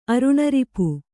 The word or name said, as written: ಅರುಣರಿಪು